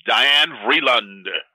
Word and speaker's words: Diane Vreeland: I want to say her name right, uh, Diane Freeland. – Diane Freeland. Diane Vreeland. Diane Vreeland.